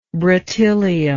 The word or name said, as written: bretylium